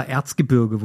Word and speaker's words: Erzgebirge: X-Terra Erzgebirge wurde er genannt. Kleiner Insider, Erzgebrick. Ich kann es nicht aussprechen. Erzgebrigge stand auf dem Flyer.